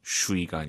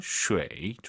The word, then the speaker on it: 水: UI 사이에 에 넣으세요. 슈이가 아니라 쉐이.